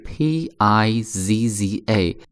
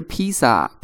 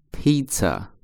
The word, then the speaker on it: pizza: 首先呢，这样的一个单词的拼写是 p i z z a，注意它读音不是披萨，披萨是中国人的读音啊，音译过来之后读音。那它正确的这个英文的读法叫做 pizza，注意它第二个音是 c c，读 pizza pizza。